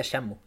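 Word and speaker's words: Shenmue: Dicen que se pronuncia Shamu. eso ya me tengo creer.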